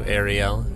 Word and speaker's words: Ariel: So it's Hebrew, Ariel is how you say the Hebrew.